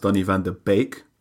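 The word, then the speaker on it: Donny van de Beek: Well, for starters, it's pronounced Donny van der Beek.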